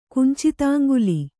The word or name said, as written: ಕುಂಚಿತಾಂಗುಲಿ